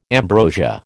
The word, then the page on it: ambrosia: American English